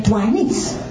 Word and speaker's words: Duanice: And the real reason I didn't need to give her a name, a nickname, there are two syllables in Duanice. In Delicia, there are three syllables. So when I would go on a rampage, I would get tongue-tied trying to say Delicia. It was just too much to say. So I started saying Lisi, because you can put emphasis on it when you're mad. You know, Lisi! Get in here, girl! I didn't have to do that with Duanice. I already had my two syllables.